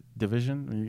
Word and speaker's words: DVSN: Is that how you pronounce it? DVSN. – Division. – Because I've been saying division, even though I don't like to give people credit like that.